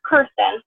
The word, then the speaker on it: Kirsten: One thing to start things off, my name is pronounced Kirsten. – Oh, it's the least common pronunciation, so don't feel bad.